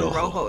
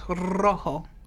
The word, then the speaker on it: rojo: You gotta roll your R's, morenos. – I'm trying. – He said rojo. It's rojo.